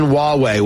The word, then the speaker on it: Huawei: If you'll remember that we were talking about ZTE and Huawei, which is spelled H-U-A-W-E-I, Huawei.